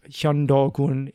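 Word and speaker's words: Hyundogun (현도군): The Lintun Commandery, um, in, pronoun- in Korean, it's pronounced Imdun Gun, uh, BCE 107 to CE 82, the Xuantu Commandery, we'll be covering a lot of these in the next episode, pronounced Hyundogun in Korean.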